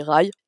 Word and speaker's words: Rhy: qui est le prince Rai, il y a plusieurs prononciations. On n'a pas tous, on n'est pas tous dans la même team, mais ça s'écrit R-H-Y. Donc moi je dis Rai, qui est un personnage que j'ai adoré.